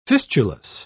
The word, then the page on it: fistulous: British English